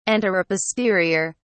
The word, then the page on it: anteroposterior: British English